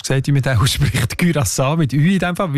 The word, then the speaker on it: Curaçao: Mit Ü, genau. Das ist der holländische Einfluss. Und wo, weil es gehört ja zu Holland, ganz offiziell. Das sind eben die Aussprachendatenbank. Die haben nämlich gerade letzten Dezember die Aussprachempfehlung geändert von Curaçao auf Curaçao, weil sie beziehen sich dort auf eine Aussprachenduden und auf das digitale Wörterbuch von der deutschen Sprache, wo beide eben Curaçao vorschreiben.